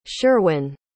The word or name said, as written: Sherwyn